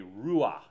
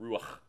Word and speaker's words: ruach: Okay, ruach is the word in Hebrew with that guttural ch, ruach is how you say it.